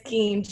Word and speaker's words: Gein: Oh, no, it's Gein, G-E-I-N, yeah.